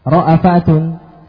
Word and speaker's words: ra'fah: Kata ra'fa di sini Ibnu Katsir membacanya dengan ra'afa. Wala ta'khudhukum bihima ra'afatun, ra'afatun di hamzah oleh Ibnu Katsir. Wala ta'khudhukum bihima ra'afatun fi dinillah. Ini faedah. Jadi kalau antum mendengar qiraah murattal dibaca hamzah, jangan antum salah baca ini. Nah, lihat dulu riwayat dari siapa.